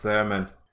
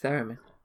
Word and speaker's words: Theremin: I think it's pronounced Theremin. – Theremin. – Mm. – Right. It's Theremin. – Anyway. – Right. – Go on. That's how you say the musical instrument anyway.